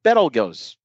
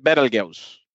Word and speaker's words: Betelgeuse: Well, not really, but the star Beetlejuice, which is a few hundred light years away. – Is that how you pronounce it? I thought you pronounced it Betelgeuse.